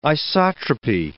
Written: isotropy

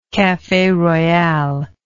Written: cafe royale

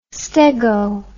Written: stego

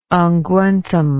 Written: unguentum

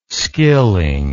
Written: skilling

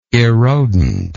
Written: erodent

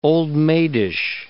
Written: old-maidish

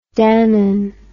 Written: Danone

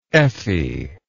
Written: effy

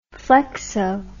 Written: flexo

